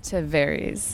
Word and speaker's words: Tavares: And we are at this really cool spot, and we're in Tavares, hopefully I've said that right. – Tavares, yeah.